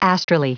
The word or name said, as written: astrally